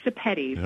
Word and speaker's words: Sepetys: Is it Sepetys? – Close enough. It's Sepetys. Okay, Sepetys. Okay.